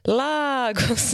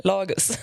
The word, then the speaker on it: Lagus: Jag berättade att det var finskt och då tyckte du att det bör uttalas Lagus istället för Lagus.